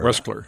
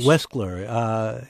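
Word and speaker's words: Weschler: He'll be appearing in conversation with journalist, let me say his name right, Lawrence Weskler. – Weskler, yes. – Weskler.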